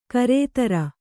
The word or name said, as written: ಗಿರಿಧರ